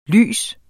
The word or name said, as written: lyst